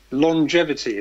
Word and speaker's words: longevity: Longevity is a good word. You have to pronounce it slightly differently. It's not long, it's lon how do you say it? It's, it's longevity, meaning, meaning living a long time.